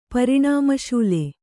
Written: ಪರಿಣಾಮಶೂಲೆ

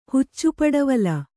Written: ಹುಚ್ಚುಪಡವಲ

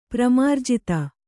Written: ಪ್ರಮಾರ್ಜಿತ

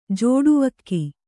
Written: ಜೋಡುವಕ್ಕಿ

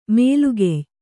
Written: ಮೇಲುಗೆಯ್

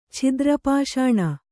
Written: ಛಿದ್ರಪಾಷಾಣ

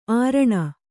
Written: ಅರಣಿ

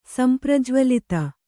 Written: ಸಂಪ್ರಜ್ವಲಿತ

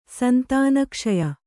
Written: ಸಂತಾನಕ್ಷಯ

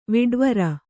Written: ವಿಡ್ವರ

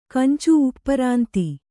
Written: ಕಂಚು ಉಪ್ಪರಾಂತಿ

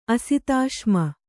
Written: ಅಸಿತಾಶ್ಮ

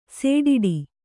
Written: ಸಡ್ಡು

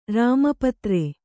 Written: ರಾಮಪತ್ರೆ

